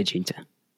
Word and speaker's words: Jeter: Najee Jeter, or Jeter. – I would say Jeter.